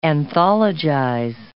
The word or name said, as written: anthologize